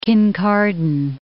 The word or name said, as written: Kincardine